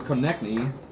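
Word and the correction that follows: Konecny: from uh, Mediapolis, getting out of the end zone. Konechny. Fineshny, thank you.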